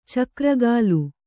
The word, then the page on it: ಚಕ್ರಗಾಲು: kannada; cakra gālu